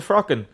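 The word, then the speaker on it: fraochán: That's a frockin. So, but you spell it F-R-O-C-K-I-N.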